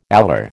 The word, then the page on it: alar: American English